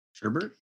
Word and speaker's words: sherbet: oh yeah, how do you guys pronounce uh, that word? Sherbert. Sherbert. with an R, with a second R, right?